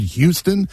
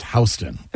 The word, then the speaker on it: Houston: And when you are there, keep in mind, it's Houston Street. Even though it's spelled Houston, okay. – It's pronounced Houston.